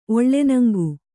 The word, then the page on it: ಒಳ್ಳೆನಂಗು: oḷḷenaŋgu